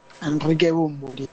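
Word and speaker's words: Enrique Bunbury: En 1967 nace Enric Bumburi, pienso que se dice así, cantautor español. – Enrique Bumburi. – No sé si que se dice. – O Bumburi. Enrique Bumburi.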